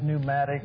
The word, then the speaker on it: pneumatic: Now, our word pneumatic, you know, the P is silent.